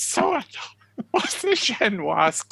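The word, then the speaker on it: génoise: And she says, prepare a, I don't know whether you pronounce it, some people pronounce it Genoese, don't they? And if you're being very French, if you've actually got a Paris balcony, then you would pronounce it Genoise.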